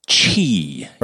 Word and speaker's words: Qi: Chi. – Right? – Yes. – Q-I pronounced chi.